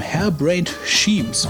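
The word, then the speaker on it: Harebrained Schemes: Wie angekündigt hat Hairbrained Schema, äh, Hairbrained Schemes, würde ich sagen, spricht man das aus, zeitgleich mit der Freigabe der macOS 10 und Windows-Version des Cyberpunk-Rollenspiels Shadowrun Hong Kong auch eine Linux-Variante des Titels herausgegeben.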